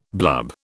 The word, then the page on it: blub: British English